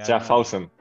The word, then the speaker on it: Schaffhausen: Schlaufasen. No, es que no, sé. Mi, Schaffhausen.